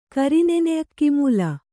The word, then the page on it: ಕರಿನೆನೆಅಕ್ಕಿಮೂಲ: Kannada